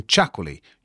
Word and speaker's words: Txakoli: This time it's the unusual sounding chakoli. You'll be forgiven for never having heard of it. It's spelt T-X-A-K-O-L-I.